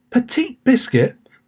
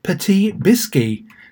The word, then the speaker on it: Petit Biscuit: Petite Biscuit, that's how it's written, but it's pronounced Petit Biscuit, because he's French, is the stage name of Mehdi Benjaloun.